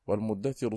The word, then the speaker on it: المدثر: والمدثر